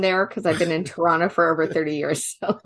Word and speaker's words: Toronto: I think when you say Torontonian, it's the only time that someone from here will pronounce the second T. Otherwise, if you want to seem like you're a local and blend in with everyone, just call it Toronto. Like it's just spelt with a bunch of N's.